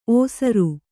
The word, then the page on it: ಓಸರು: kannada